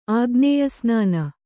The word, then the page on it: ಆಗ್ನೇಯಸ್ನಾನ: kannada